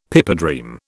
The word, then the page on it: pipedream: British English